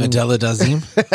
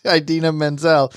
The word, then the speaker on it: Idina Menzel: My favorite award show announcement thing will always be John Travolta mispronouncing Adela Dazeem. – Idina Menzel.